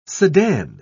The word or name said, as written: sedan